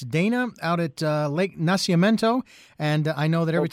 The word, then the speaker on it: Nacimiento: It's pronounced Nacimiento. – Nacimiento.